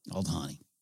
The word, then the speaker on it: Aldhani: Aldhani. I added an A up top, which I do often.